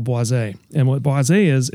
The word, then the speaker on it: boisé: So boisé, spelled like Boise, is apparently in cognac.